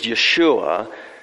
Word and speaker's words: Yeshua: In Hebrew, the name Jesus, or the way you would pronounce it in Hebrew is Yeshua, the name Jesus means Yahweh, or the covenant Lord Himself, is salvation.